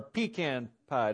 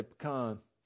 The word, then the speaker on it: pecan: Raise your hand if you like a good pecan pie. Or pecan pie. Did I say that wrong? Up in the north, we say pecan.